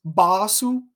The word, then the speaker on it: Basu: Normally, his name would be pronounced Basu with a short A there at the beginning. Instead, he is uh, called by the fans Basu, an elongated A there.